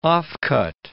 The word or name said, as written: offcut